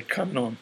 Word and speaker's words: Kannon: In Japanese, we should say Kannon. It's just a Japanese pronunciation of the same name.